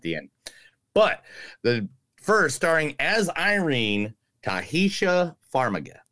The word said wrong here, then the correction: Taissa Farmiga: Taissa Farmiga. Actually, I was very close.